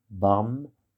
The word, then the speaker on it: Barmherzigkeit: Barmherzigkeit. Ne soyez pas surpris, le IG de Herzig se prononce comme si c'était Z-I-C-H-K-E-I-T.